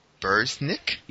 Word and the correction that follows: Busiek: Busiek? – Oh, Busiek. – Yeah, there we go.